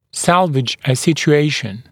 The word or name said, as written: salvage a situation